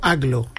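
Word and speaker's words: Agloe: Entiendo que Aglo, yo lo he escuchado pronunciado como Aglo.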